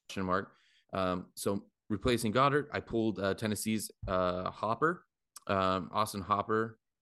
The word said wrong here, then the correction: Hooper: Hooper, however you want to say his name. Is it Hooper? – I think it's Hooper. Austin Hooper. – Yeah, it's double O. It's Hooper.